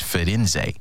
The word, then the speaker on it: Firenze: And if you know anything about Florence, you know that its name in Italian, it's Firenze. It's spelled Firenze.